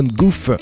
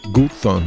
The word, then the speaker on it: Gough: Il s'appelle Damon Goof, ou Goof, ça s'écrit G-O-U-G-H, mais enfin bon, le projet à retenir, c'est le nom, donc c'est son nom de scène, hein, Badly Drowned Boy.